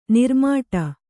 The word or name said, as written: ನಾರುಮೋಡ